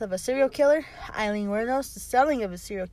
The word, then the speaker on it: Wuornos: Wuornos? – Wuornos.